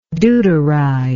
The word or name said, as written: deuteride